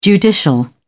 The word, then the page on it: judicial: British English